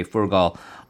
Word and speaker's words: Furgal: At issue is the arrest of this popular governor by the name of Sergei Furgal on what people are considering to be spurious murder charges, which extend back as far as 2004.